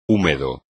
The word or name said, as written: húmedo